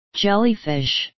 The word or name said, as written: jellyfish